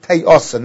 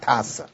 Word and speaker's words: te'osa: The Malbim says, as I've been careful to pronounce the word properly, the Torah says the word te'osa, not tasa.